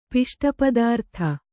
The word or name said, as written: ಪಿಷ್ಟಪದಾರ್ಥ